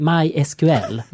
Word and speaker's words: MySQL: De har sagt här uttalningsmässigt att det heter MySQL. Nej, MySQL. Mm. För att de vill verkligen inte att det ska vara MySQL.